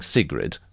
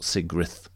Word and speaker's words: Sigrid: Now, Sif Boynton has corrected our pronunciation of Sigrid the Haughty. Because we initially were saying Sigrid, and then after our chat with the Saga thing chaps, they said they thought Sigrith was more realistic.